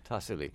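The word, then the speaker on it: Tassili: How do you say it? – Tasili.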